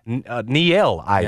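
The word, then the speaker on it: Niele Ivey: Coach, now Notre Dame, Nielle Ivey.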